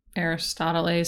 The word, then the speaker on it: Aristoteles: Aristoteles sounds like Aristotle's, and he's a philosopher.